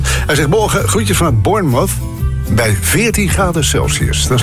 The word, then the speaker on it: Bournemouth: Je moet het volgens de Engels uitspreken als Bournemouth. Bournemouth. Een beetje inslikken.